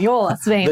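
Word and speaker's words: Jólasveinar: As long as you don't pronounce the J as a J. – Right. – You're pretty good. Jolas Vaynar.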